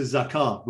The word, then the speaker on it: zakat: Um, you're using, I suppose, the, the, the Arabic pronunciation zakat, which of course in compound form becomes zakat.